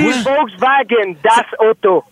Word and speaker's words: Volkswagen: C'est Volkswagen. – Volkswagen, oui, c'est ça. – Ouais, le W en allemand, tu le prononces comme un V.